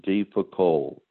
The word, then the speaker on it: de Foucauld: The Prayer of Abandonment, and the author is Charles D. Foucault. I'll spell it. D-E-F-O-U-C-A-U-L-D.